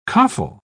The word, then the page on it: coffle: British English